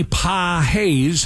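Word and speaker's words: Pagés: Then you go Andy Pahaez. How do you say his name? From That's right. Yeah, you got it.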